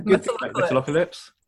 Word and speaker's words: Metalocalypse: Metalocalypse.